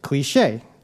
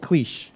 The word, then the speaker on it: cliché: So, for example, if I said it's a trite or overused expression, you would say cliché. The accent over the E tells me not to pronounce it cliche.